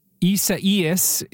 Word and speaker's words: Isaias: Isaias is how you say the name, I believe.